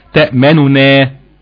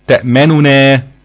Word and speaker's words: تأمنا: ولكن الروم هيبقى في عندنا هنا نونين، هيتم اختلاس الحركة في النون الأولى، تبقى تنطق إزاي؟ تأمننا، تأمننا. تمام؟ هيختلس الحركة في النون الأولى، يبقى له فيها الروم والإشمام، الروم مع اختلاس في النون الأولى والإشمام مع الإضغام.